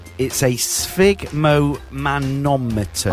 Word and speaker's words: sphygmomanometer: I thought it was called a sphincthermometer, but... – I don't even think I can pronounce it. – Go on, pronounce it slowly. – Right. It's a sphygmomanometer.